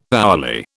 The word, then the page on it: hourly: British English